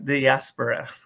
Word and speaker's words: diaspora: the word i'll say dysphoria diaspora diaspora okay i'll say dysphoria diaspora okay that's how you say it